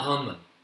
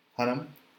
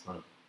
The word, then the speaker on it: Hunnam: It's not Hunman. Hunnam. It's Hunnam. Hunnam.